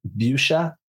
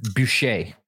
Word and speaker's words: Bucher: On the last episode, we ended with me saying we were going to start this episode talking about an article from Ric Bucher. Bucher. Bucher. Bucher. Bucher. Sorry, Ric. – Um, that's, the, that's the, English pronunciation. – Ric Boucher. Ric Boucher. That'd be French.